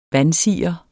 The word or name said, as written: vansire